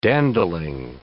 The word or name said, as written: dandling